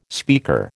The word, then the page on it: speaker: American English